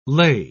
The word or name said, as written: ley